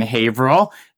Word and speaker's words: Haverhill: So now you're the owner, founder, president of Cassidy Paving in Haverhill. Did I get that pronunciation right? – Yes, you did.